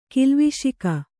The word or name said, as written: ಕಿಲ್ವಿಷಿಕ